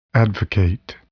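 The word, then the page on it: advocate: verb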